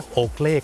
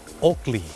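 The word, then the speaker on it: Oakley: โอเกลครับเขาออกเสียงว่าโอกลี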